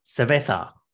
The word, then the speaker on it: cerveza: I got a sober finger lime cerveza. How do you say that? Cerveza? – Cerveza.